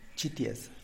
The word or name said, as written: txitias